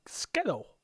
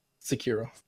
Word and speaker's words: Sekiro: Division 2 and Skittle. – Sekiro.